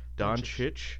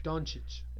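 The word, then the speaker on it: Dončić: Doncic. – Doncic.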